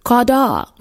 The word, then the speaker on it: chadah: It's pronounced kedvah, and it's spelled C-H-E-D-V-A-H, kedvah, which means rejoicing or gladness. And the root of the word is Hebrew too, kadah, which is spelled C-H-A-D-A-H. Kadah, which means to rejoice, make glad, be joined.